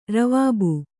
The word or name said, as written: ರವಾಬು